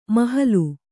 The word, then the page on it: ಮೊಹಲ್ಲೆ: kannada